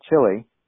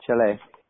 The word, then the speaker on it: Chile: We as gringos pronounce it Chile, but it's pronounced Chile.